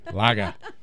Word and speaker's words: Laaga: L-A-A-G-A. Laga.